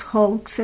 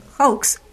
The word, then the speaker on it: hoax: Bueno, el día de ayer estaba escuchando que estaban hablando sobre estos famosos hoax, estos engaños, fraudes, ¿Cómo se llaman? – Hoax. Se escriben H-O-A-X, es una palabra inglesa.